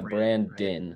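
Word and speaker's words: Brandin: He spells it with an I, but it's pronounced Brandon.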